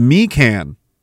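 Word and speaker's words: Mikan: McDonald Parkway is named, okay, pronounced Meekan, okay, meaning Great River Road in the Algonquin language.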